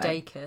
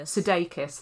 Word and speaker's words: Sudeikis: Sudeikis. – Sudeikis, thanks.